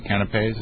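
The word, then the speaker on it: canapés: That that I'm excited about is that because of the presentation of the canapes, is that how you pronounce it? – Yes.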